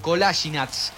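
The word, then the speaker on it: Kolašinac: Lateral cerrada sobre la izquierda para una ocasión que tenga Sead Kolasinac. O mejor dicho, Kolasinac, así como el señor lo pronuncia.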